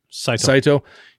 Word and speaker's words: Saito: Saito.